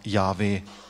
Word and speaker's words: Yahweh: Aber wenn man die Sprachstudien macht, wie diese Wurzel JHWH tatsächlich hergeleitet wird, dann ist es sehr wahrscheinlich, dass das richtig ausgesprochene Wort Yahweh ist.